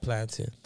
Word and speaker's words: plantain: How you say mountain? – Mountain. – How you spell mountain? – Don't do me. Mountain with A-I-N. – Okay. How you spell plantain? – Plantain. Because it's not, we're not used to that. So it's like, they know how to say it. We don't like Jamaicans. – Mountain, plantain.